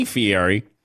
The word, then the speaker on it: Fieri: Yeah, you bring all of your fingers have to come together in order to properly pronounce his name, Fieri.